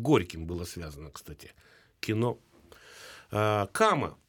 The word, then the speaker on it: кама: Ирина, вы почти правильно назвали, ну не кади, а кама.